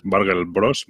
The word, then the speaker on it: Burgle Bros: Burgle Bros. Burgle Bros. Voy a deletrearlo porque a mí me costó encontrarlo. Es Burgle y luego Bros, como Mario Bros, del Hermanos, ¿vale?